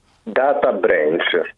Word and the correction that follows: data breach: Guarda, breach, per carità del cielo, perché c'è un ascoltatore che ci sta tormentando da giorni, sgridando perché pronunciamo male. Data breach.